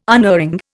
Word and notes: unerring: Indian

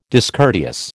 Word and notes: discourteous: American English

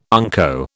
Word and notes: unco: British English

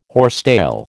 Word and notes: horsetail: American English

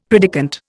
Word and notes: predikant: Indian

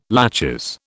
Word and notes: laches: British English

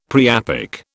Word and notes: priapic: British English

